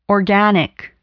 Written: organic

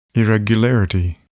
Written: irregularity

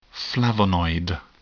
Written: flavonoid

